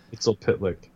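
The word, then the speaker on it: Mxyzptlk: Well, they had that episode where, last season, where she came over against, like, the, Mr. Mitzi Plix or whatever. – Mitzel Pitlick. – Whatever. I can't pronounce the name. – I can say it because it was really easy to say in Smallville. Mitzel Pitlick.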